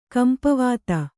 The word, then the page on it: ಕಂಪವಾತ: kampavāta